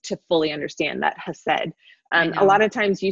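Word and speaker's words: hesed (חסד): It's hesed. It's spelled with a CH, but we think it's hesed.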